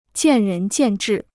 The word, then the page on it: 见仁见智: jiàn rén jiàn zhì